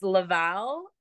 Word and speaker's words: Laval: It's Laval, Quebec.